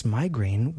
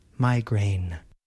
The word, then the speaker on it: migraine: In American English, we pronounce migraine with two vowel sounds, I and A. The first syllable has the I vowel sound, my. And the second syllable has the A vowel sound, grain.